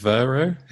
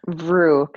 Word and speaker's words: Vrew: Is it Vero? Is that how you say it? – Vrew, kind of like Vrew for you.